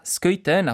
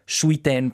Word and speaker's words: Schuiten: diciamolo giusto, François Schuiten, appunto gli, da italofoni leggeremmo Schuiten probabilmente, visto che si scrive con Schui, ma l'origine belga, ecco, ci impone questa pronuncia.